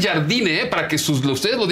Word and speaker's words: Jardine: no se dice jardiné, dice jardine.